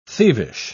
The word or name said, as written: thievish